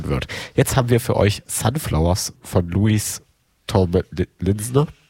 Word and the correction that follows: Louis Tomlinson: Linson? Wie wird's ausgesprochen? Kann mir jemand helfen? – Sunflowers von Louis Tomlinson.